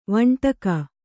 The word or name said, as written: ವಂಟಕ